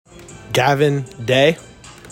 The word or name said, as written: GAVIN DAY